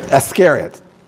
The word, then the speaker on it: Iscariot: He was speaking about Judas, the son of Simon Iscariot. Did I say that right? – Uh-huh. – Iscariot.